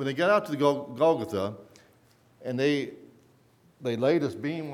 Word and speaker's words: Golgotha: There was a mountain or hillside out on the outskirts of Jerusalem called Golgotha. We often say Golgotha, but the correct pronunciation is Golgotha.